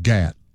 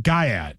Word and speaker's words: Gyat: Now, how you spell that? – G-Y-A-T. – Oh, I was thinking Gat, like in, you know, are you strapped, you know. – But no, it's not. – No. – Yeah, that would make more sense. Gyat, it's a term that has been around for a while, and it's starting to show up with a younger white audience. – They say the Y, the A, and the T are silent. – Yeah. – Yeah, so that's how you pronounce it.